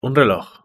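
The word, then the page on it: un reloj: Spanish (Spain)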